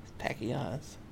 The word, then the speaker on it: tachyons: Tachyons.